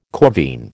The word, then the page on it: corvine: American English